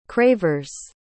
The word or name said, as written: cravers